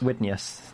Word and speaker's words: Widenius: Michael Widenius is, I guess. – Widenius. – Widenius, that would be how you pronounce that.